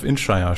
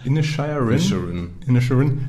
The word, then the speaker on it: Inisherin: Aber jetzt habe ich gesehen, dass diese Woche schon The Banshees of Inisherin startet. Und ich habe das hundertprozentig falsch ausgesprochen. Mhm. Da heißt es Inisherin.